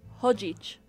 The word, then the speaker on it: chodzić: And you have that h sound, even though it's the ch. Which chodzić.